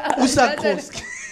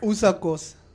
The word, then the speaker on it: Usakos: No, it's like Usakos. – Usakos. – Usakos. – No, but I'm saying it correct. Usakos. – Now you're saying it correctly.